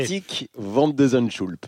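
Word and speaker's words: Botic van de Zandschulp: Botik van de Zandschulp.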